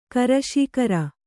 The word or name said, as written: ಕರಶೀಕರ